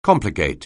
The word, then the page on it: complicate: anglais